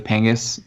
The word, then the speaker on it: Pangus: and you can look me up at Charlie Pangus. My name is spelled L-E-Y at the end, and then my last name's P-A-N-G-U-S.